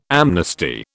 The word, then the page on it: amnesty: British English